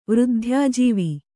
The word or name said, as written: ವೃದ್ಧ್ಯಾಜೀವ